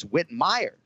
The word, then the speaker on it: Whitmire: You got to go listen to it because it's Whitmire. So emphasis on the second syllable.